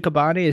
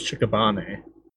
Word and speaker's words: Shikabane: So, in the dub, they did pronounce it Kabane, it's not Shikabane? – No, it was Shikabane.